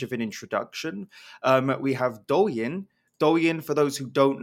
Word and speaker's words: Douyin: Douyin.